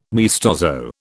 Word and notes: maestoso: British English

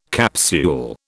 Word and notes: capsule: British English

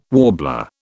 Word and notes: warbler: British English

